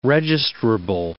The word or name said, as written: registrable